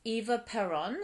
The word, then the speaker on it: Eva Perón: And usually it was like Eva Perón. Is that how you say her name? – Yep.